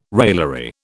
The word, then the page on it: raillery: British English